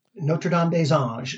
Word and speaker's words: Notre Dame des Anges: And like, for example, like some of the churches seem like they're pretty amazing, like the Notre Dame des Anges, or is that, am I pronouncing it correctly? – Notre Dame des Anges, Notre Dame of the Angels. Yes, it's a beautiful church.